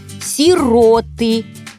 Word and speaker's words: сироты: Согласно нормам современного русского ударения, правильно, сироты. Много сирот. Помогать сиротам. Иное ударение грубо неправильное.